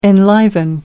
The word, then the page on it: enliven: American English